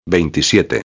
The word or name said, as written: 27